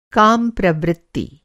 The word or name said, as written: काम प्रवृत्ति